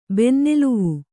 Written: ಬೆನ್ನೆಲುವು

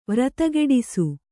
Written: ವ್ರತಗೆಡಿಸು

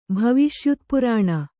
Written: ಭವಿಷ್ಯುತ್ಪುರಾಣ

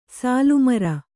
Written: ಸಾಲುಮರ

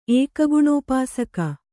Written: ಏಕಗುಣೋಪಾಸಕ

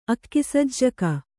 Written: ಅಕ್ಕಿಸಜ್ಜಿಗೆ

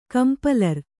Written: ಕಂಪಲರ್

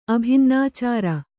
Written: ಅಭಿನ್ನಾಚಾರ

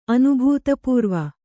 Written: ಅನುಭೂತಪೂರ್ವ

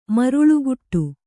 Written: ಮರಳುಗಾಡು

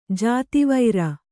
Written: ಜಾತಿವೈರ

